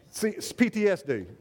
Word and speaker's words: PTSD: Or they say this, we need to be more respectful for our veterans who might have COPD and with all these fireworks. PTSD, excuse me, PTSD, thank you. I knew I said it wrong because Carrie was laughing.